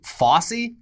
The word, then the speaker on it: Fauci: But his Fauci side of the family, the other Anthony Fauci, they pronounce it Fossey. So... – Like Fossey tube? – I guess.